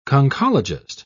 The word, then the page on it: conchologist: British English